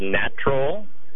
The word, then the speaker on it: Gnatrol: It is a, usually a concentrated liquid, occasionally a powder that is used particularly against fungus gnats, and it's called Natrol, which is spelled G-N-A-T-R-O-L.